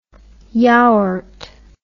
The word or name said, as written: yaourt